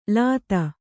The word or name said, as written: ಲೂತಿ